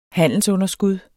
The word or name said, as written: handelsunderskud